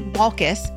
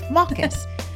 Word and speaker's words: Waalkes: This is Katie Waalkes. Did I say that right, Katie? – Yes, you did. – Nice. I'm so proud of myself. Waalkes.